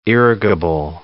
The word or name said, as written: irrigable